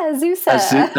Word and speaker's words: Azusa: Did I pronounce that correctly? – Yeah, Azusa.